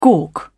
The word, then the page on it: gawk: British English